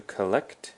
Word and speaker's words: collect: So to collect has the emphasis on the second syllable, collect. And that's a verb that means 收集.